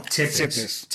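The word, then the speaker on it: Țepeș: Me escribió el nombre, el Chepes, la T, sí. – con un rabito debajo, que es como se pronuncia, y me explicó, en fin, el origen de la palabra.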